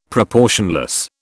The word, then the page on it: proportionless: British English